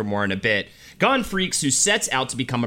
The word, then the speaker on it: Freecss: No, Gon, G-O-N, and his last name is F-R-E-E-C-S-S, which is pronounced Freecss, and it's actually purposely freaky.